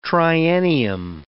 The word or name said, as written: triennium